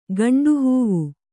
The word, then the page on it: ಗಂಡುಹೂವು: Kannada